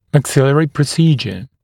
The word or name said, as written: maxillary procedure